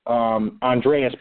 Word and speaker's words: Andreas: That's written by a guy named Andre Paris, Andreas Paris, actually. First name is spelled A-N-D-R-E-A-S. The last name, just like the country, Paris, P-A-R-I-S.